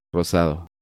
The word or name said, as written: Rosado